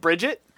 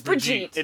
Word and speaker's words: Brigitte: Isn't it Bridget? – No, actually, it's Brigitte. – It is Brigitte? – Really? It's Brigitte.